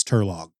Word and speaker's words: Turlough: I may have been pronouncing it wrong. Since there is no U between the O and the GH at the end, then yeah, I guess it's Turlog.